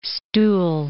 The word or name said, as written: stool